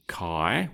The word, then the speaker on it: Kai: And we will call him Kai, which rhymes with Guy.